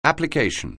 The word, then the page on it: application: anglais